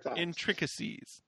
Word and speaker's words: intricacies: Man, if you can't tell me the intrinsic ecstasies of how to pronounce that word. – Booby traps. – If you can't tell the intricacies of how to pronounce that word. That word is booby trapped. And it has murdered me every time. – That's intricacies? Booby trapped. – Five more times. – Intricacies. – There we go.